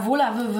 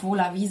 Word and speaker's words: Wurlawy: Ähm, ja, Volave wird es korrekt sorbisch ausgesprochen, mhm. man kann aber auch Volavi sagen, weil es irgendwie ein Eigenname ist. Und ähm, das machen sowieso die meisten, deswegen können wir auch Volavi sagen.